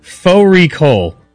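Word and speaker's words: fårikål: Faux recall, which is a mutton and cabbage. It's spelt like far recall.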